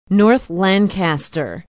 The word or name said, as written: north lancaster